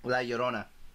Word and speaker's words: La Llorona: And then that weekend also we have The Curse of La LaRue, which is a horror movie. – La Llorona.